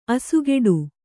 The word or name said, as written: ಅಸುಗೆಡು